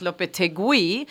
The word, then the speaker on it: Lopetegui: I've learned in the doing Lopetegui. Some research in this trauma that we've had that actually for it to be pronounced Lopetegui, there would have to be two dots over the U.